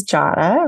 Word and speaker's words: Jadah: So for me, I always say my name is Jadah, rhymes with Prada. Some people say might say Jada.